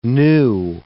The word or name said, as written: gnu